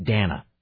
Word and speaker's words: Dana: Hey, by the way, just as a side note, when did people whose names were spelled D-A-N-A start pronouncing that Dana? It's got one N, it's Dana. Two N's is Dana. – I don't know anybody named Dana. – Dana Bash from CNN, D-A-N-A, Dana Bash.